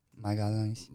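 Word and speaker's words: Magalhães: How do you say it? Magalhaes? – Magalhaes. – Magalhaes. Gabriel Magalhaes. That other Gabriel, he was a bozo, bro.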